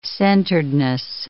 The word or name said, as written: centeredness